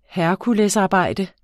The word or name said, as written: herkulesarbejde